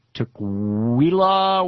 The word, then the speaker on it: Tukwila: And Carl in Tukwila, Washington.